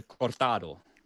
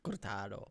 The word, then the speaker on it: cortado: Hur, hur uttalar man det? – Ja, men jag har ju bott i Spanien vet du. Cortado. Cortado.